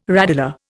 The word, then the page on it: radula: Indian